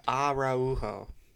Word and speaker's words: Araujo: Let's talk about Cesar Araujo, the 20-year-old midfielder who is joining from Montevideo in Uruguay. – Araujo. – Yes, the American way of pronouncing it. Well done.